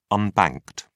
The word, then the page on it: unbanked: British English